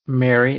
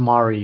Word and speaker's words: Mari: Mary, it's Mari, you dumbass. So say it right, Dillahunt.